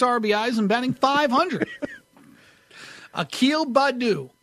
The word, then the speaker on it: Akil: His first name, A-K-I-L, that's Akil. – Akil Badu, yeah. – Badu, B-A-D-D-O.